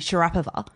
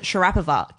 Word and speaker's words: Sharapova: Like, for example, apparently Sharapova is supposed to be Sharapova. Oh. And anyone who pronounces it Sharapova gets slammed in the media because they're like, why are you being so arrogant and pronouncing it the right way? – Ibiza versus Ibiza.